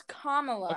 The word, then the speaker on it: Kamala: No, it's Kamala.